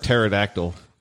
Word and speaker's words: pterodactyl: I was trying to figure out how to work pterodactyl because the P is silent.